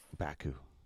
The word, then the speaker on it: Baku: Baku.